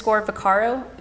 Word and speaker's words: Vaccaro: They can find me at Keeta, K-E-E-T-A, _ Vaccaro, V as in Victor, A-C-C-A-R-O, and my company name is Mizuare Collection.